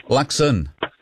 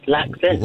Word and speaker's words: Luxon: It doesn't matter whether it's Luxton or who it is. – Hey, someone had to go at me for mispronouncing the Prime Minister's name, which I did not do. Luxon. – Oh, right, Luxon.